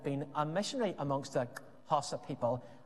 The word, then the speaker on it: Xhosa: I say Xhosa with the click because they're from South Africa and the Xhosa people speak with clicks.